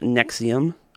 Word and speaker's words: NXIVM: But first, you have probably heard about this group called Nexium, or as it's spelled, N-X-I-V-M, which, you know, it seems like it should be a running shoe or something, or...